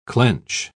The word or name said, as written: clench